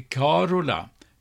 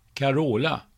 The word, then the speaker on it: Carola: Det äldre uttalet i Sverige är Carola med betoning på första stavelsen i likhet med det latinska mansnamnet Carolus. Senare har namnet med betoning på andra stavelsen, Carola, blivit det vanliga.